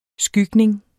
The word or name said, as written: skygning